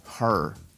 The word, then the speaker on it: her: Con estas primeras dos me hacía bolas, porque cabello se dice hair, hair, y ella se dice her. Pero yo decía, cuando veía la E, yo pronunciaba hair para, usar el pronombre, el pronombre personal. Cuando, estaba diciendo, cuando decía eso, estaba diciendo cabello. Hair her.